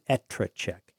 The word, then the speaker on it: EtreCheck: I'm going to pronounce it Etrecheck.